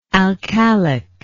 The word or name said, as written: alkalic